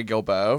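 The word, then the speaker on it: Guilbeaux: But one of my favorites is Mary Gilboe from American Idol. And that's G-U-I-L-B-E-A-U-X is how you spell it, of course.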